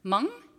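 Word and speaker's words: meng (忙): This character is pronounced meng, and it's the Chinese word for busy.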